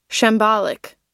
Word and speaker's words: shambolic: Might not even be saying it right, but it's in the complaint. We have called Michael Gableman's investigation haphazard, botched, ham-handed, chaotic. Apparently shambolic is one of those words that I wish had been in my vocabulary earlier. – Shambolic. – Yep, okay. I guess you're saying it correctly.